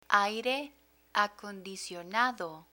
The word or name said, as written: aire acondicionado